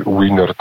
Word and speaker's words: Wiener: No tutaj matematyk, tak, od którego się zaczęła, który się uznaje jako ojca cybernetyki, jako osobnej nauki, tak, czyli tutaj Norbert Wiener, tak, czy Wiener, tak jak Amerykanie wymawiali,